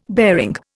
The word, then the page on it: bearing: Indian